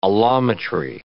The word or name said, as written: allometry